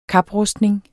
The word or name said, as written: kaprustning